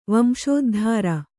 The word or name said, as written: ವಂಶೋದ್ಧಾರ